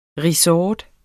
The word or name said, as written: resort